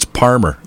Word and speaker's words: Parmer: You don't spell it with an L. It's always with an R. P-A-R-M-E-R. Did you know that, Jen? It's Palmer. You can't say Palmer. It's Palmer. P-A-R-M-E-R. Like Peabody. Like Peabody. It's P-E-B-D-Y.